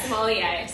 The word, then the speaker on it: sommelier: So then I actually didn't even know this word before we did this, but sommelier, is that how you say it? – Yeah, sommelier.